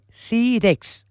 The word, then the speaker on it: Sírex: Uau, uau, uau, el sílex. A veure si ho pronuncio bé. Sírex.